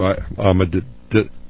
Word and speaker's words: Ahmadinejad: I have a hard time pronouncing that. But you know who I'm speaking of. Ahmadinejad. – That's a pretty good try, yes.